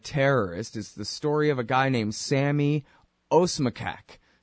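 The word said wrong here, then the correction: Osmakac: Is that right? – Osmakash is how it's pronounced.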